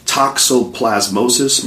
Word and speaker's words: toxoplasmosis: I had heard something about toxoplasmosis.